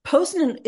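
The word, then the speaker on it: Poznań: And then Poznan, am I saying that right? – Yes, Poznan is fascinating.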